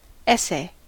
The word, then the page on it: essay: American English